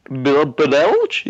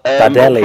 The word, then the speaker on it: Badelj: Rakitic, Modric, and whoever plays in behind, whether it's Beljar, I think it is. I've not looked at the pronunciation sheets. I've probably got that wrong. Sorry, lads. – Badelj? – Bardelli, yeah, that's the fella.